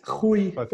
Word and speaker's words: Rui: Both of them are right. Rui is how we pronounce it.